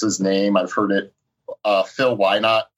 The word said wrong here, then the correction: Lynott: It's Lynott. – Lynott? – Yeah.